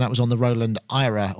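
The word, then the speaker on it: AIRA: And she says, we don't pronounce it like that anymore. I'm like, what anymore? It's not even out yet. – Did she tell you? – We've pronounced it Ira. So you pronounced it correctly. Ira.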